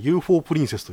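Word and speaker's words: 円盤王女: 円盤王女、これはね、 UFO プリンセスと読みます。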